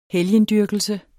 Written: HELGEN

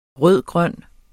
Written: rød-grøn

